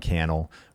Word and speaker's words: Cannell: You could tag official Think Media on Instagram or at Sean Cannell, rhymes with YouTube channel on Instagram.